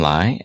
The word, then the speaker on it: Li: It's a quirk of my last name where it is spelled uh, like Jet Li, but sure. Um, our family pronounces it just a bit differently.